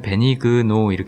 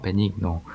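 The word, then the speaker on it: Benigno: 베니그노는 참고로 이게 자막에는 베니그노 이렇게 나오는데 에, 실제 발음은 약간 베니노에 가까워요. 베니노. 어, 아무튼 베니그노는 어, 아까 굉장히 중요한 대사를 하나를 하죠.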